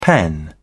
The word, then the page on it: pen: British English